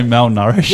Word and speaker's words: malnourished: But if a dog's been now nourished, is that the word? What, how do you say that word? – Did you try and say malnourished? – Yeah, I started with an N.